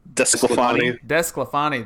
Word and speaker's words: DeSclafani: I don't even know how to say his name. – Desclafani. – There it is.